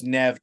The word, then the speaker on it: Neve: I think it's pronounced Neve Campbell. – Oh, is it actually Neve Campbell? I mean, yeah, Neve Campbell. I believe it's Neve. That's how I say it.